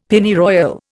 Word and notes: Pennyroyal: Indian

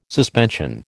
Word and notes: suspension: American English

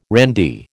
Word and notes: randy: American English

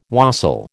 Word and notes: wassail: American English

